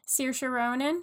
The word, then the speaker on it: Saoirse Ronan: Saoirse Ronan.